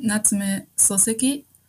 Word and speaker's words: Natsume Soseki: Es geht um Natsume Soseki und das Buch Kokoro.